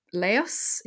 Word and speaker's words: Laos: Laos. – Laos. Okay. – I'm not actually, okay. I'm not sure if you pronounce the S or not, but. – Laos. – Yes, Lao.